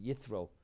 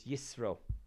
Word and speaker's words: Yisro: In English, they pronounce it Yithro. In the Hebrew, it's Yisro, as the S sound.